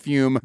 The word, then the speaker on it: Fum: That is spelled F-U-M, pronounced Fume.